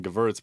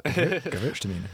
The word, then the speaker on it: Gewurztraminer: I just call them Gewurz, but you can. – That's good. That's it. Let's go with that. – Gewurztraminer. – Gewurztraminer.